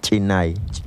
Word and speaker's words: chinay: Vamos a hablar acerca del grillo, que en la lengua yocotán se pronuncia chinay.